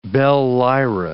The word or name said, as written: bell-lyra